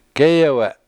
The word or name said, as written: gEyewe7